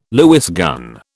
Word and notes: Lewis gun: British English

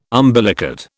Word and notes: umbilicate: British English

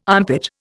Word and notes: armpit: Indian

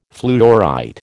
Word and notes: fluorite: American English

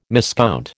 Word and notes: miscount: American English

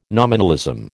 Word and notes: nominalism: American English

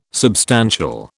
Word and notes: substantial: British English

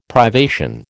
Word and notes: privation: American English

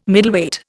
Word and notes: Middleweight: Indian

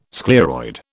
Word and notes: scleroid: British English

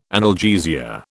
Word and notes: analgesia: British English

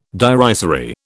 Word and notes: derisory: British English

